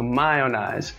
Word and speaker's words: Miownize: All right, well, it's spelled M-I-O-W-N-I-Z-E. So My Own Eyes. The M-I is my, O-W-N, own, I-Z-E is the eyes.